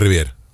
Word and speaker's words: Riviere: Sí, Mario Riviere. – Riviere, sí. – La E final no se pronuncia.